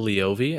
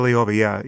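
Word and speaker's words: Leovy: is it Jill Lavoie who wrote the book? – Jill Leovi, and that's how I've been pronouncing it. – Jill Leovi, yeah, sorry.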